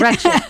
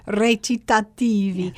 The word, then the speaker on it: recitativi: Recit, yes, so, recit or recit, yes. – Yes. – Absolutely. – But technically speaking, the right way to pronounce it would be recitativi.